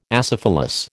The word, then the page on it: acephalous: American English